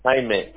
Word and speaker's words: Jaime: You can call me Jaime. – Sorry, what, can I call you Jaime? – Yeah, my first name is Jaime. – Jaime. I'm pronouncing it right, Jaime. – No, Jaime. J-A-I-M-E. – J-A-I-M-E, yeah? – Yeah.